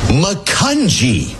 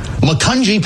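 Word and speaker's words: Macungie: Makunji. It's a soft G. Makunji, Pennsylvania.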